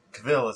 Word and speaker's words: Cavill: Henry Cavill, Cavill, as I was saying, is pronounced like travel.